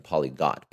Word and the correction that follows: polyglot: but it's polyglot, so I apologize for that.